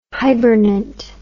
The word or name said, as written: hibernant